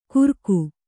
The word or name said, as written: ಕುರಕು